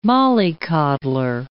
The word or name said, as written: mollycoddler